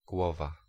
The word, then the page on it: huvud: [ˈhʉː.vɵ(d)]; /ˈhʉːˌvɵd/